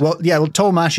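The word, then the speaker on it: Tollemache: These days, I think the Tollmash family pronounce it Tollmash.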